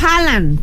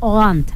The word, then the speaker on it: Haaland: Dice, así no se dice Haaland. Pues, ¿cómo se dice? Le estamos diciendo. – Es que, miren, nosotros preguntamos, en la canción de ahí, dice Haaland, Haaland, y... supuestamente se dice Holland, algo así, Holanda.